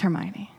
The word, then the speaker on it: Hermione: It's Hermione.